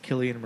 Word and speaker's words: Cillian: I like, and I'm going on record, that it is pronounced Killian Murphy. – Killian or Cillian? – And I believe it's Killian, because mm-hmm. it's like Celtic.